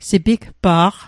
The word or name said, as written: sipigpa'q